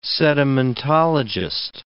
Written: sedimentologist

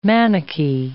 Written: Manichee

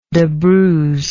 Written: debruise

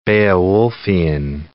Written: Beowulfian

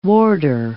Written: warder

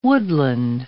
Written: woodland

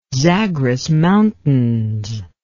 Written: zagros mountains

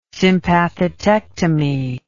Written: sympathetectomy

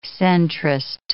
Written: centrist

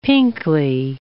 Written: pinkly

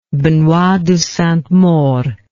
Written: Benoit de Sainte Maure

